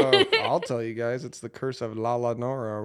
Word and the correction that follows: La Llorona: or whatever. I'm pretty I sure I pronounce it, it La Llorona. – La Llorona.